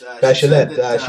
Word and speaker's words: Bachelet: And your president, Michelle Bachelet, is that how you say her name? – Yes. Bachelet? Michelle Bachelet.